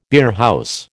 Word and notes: beerhouse: American English

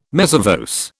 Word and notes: mezza voce: British English

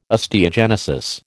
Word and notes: osteogenesis: American English

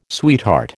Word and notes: sweetheart: American English

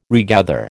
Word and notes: regather: American English

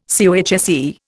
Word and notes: COHSE: Indian

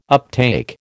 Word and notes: uptake: American English

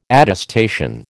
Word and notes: attestation: American English

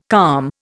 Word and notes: calm: Indian

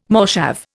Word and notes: moshav: Indian

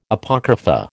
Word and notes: Apocrypha: American English

